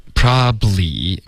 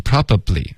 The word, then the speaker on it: probably: OK， probably 不要发成 probably， OK，用它的这个缩写的发音 probably。 OK，两个音节就够了。